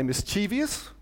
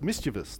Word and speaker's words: mischievous: People say mischievous, putting an extra I in when it's mischievous. There is no extra I in mischievous. It is not mischievous.